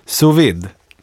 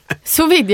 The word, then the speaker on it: sous-vide: Det handlar ju om, hur uttalar man det här? Sous-vide. – Sous-vide. – Nej, jag ska ta bort det jag sa. Sous-vide. – Sous-vide, ja.